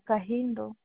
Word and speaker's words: Kahindo: The second name is Kahindo.